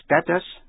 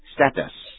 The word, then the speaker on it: status: Quý vị nghe lại chữ status, S-T-A-T-U-S, nghĩa là địa vị trong công ty hay xã hội. Nhận xét, âm A trong status, người Mỹ đọc là E, người Anh đọc là A. Vậy chữ status, đọc theo lối người Mỹ, status, đọc theo lối người Anh, status. Quý vị nghe chữ gender, G-E-N-D-E-R, tức là phái tính, nghĩa là nam hay nữ.